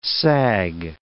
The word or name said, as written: sag